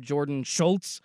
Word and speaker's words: Jordon Schultz: the pronunciation or mispronunciation that Will did of Herman, it does make me wonder, like, should I be walking around saying my name Jordon Schultz? Because it's German, right?